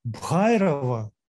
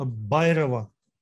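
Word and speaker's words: Бхайрава: Не Бхайрова, а Байрова. Ну, как скажет индус? Русский скажет Бхайрова. Индус скажет Байрова.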